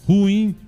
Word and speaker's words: ruim: Como em pinguim. – Ruim, então. – Ruim. – Ruim. – Acento no i. – No i. – Sílaba forte é o i.